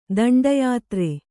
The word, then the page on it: ದಿಂಡೀಯಾತ್ರೆ: diṇḍīyātre